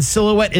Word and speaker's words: silhouette: And silhouette is S-I-L. H-O-U-E-T-T-E. That's stupid H. But congratulations. – Silent H.